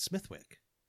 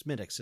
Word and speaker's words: Smithwick's: I'm sorry. Is it Smith? No, it's Smithwick. Yeah, I'm sorry. Still like Smittix. It's pronounced Smittix. It's spelled Smithwick's. But it's a Smittix Irish Ale.